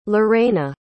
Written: lurena

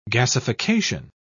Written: gasification

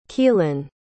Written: kelin

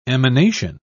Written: emanation

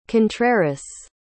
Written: contreras

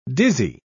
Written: dizzy

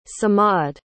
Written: samad

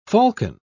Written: falcon

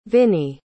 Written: vinnie

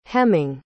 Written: hemming

